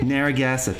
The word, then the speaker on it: Narragansett: The situation is similar in nearby coastal states, except in Rhode Island, where in Narragansett Bay, am I pronouncing that correctly? – Narragansett? – Narragansett, thank you.